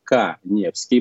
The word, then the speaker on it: Каневский: Подпишитесь на мой Инстаграм, Эдуард Каневский, моя фамилия пишется через А.